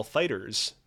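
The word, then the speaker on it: FighterZ: Dragon Ball Fighters, pronounced with an S, even though it's a Z.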